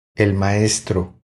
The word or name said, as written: El maestro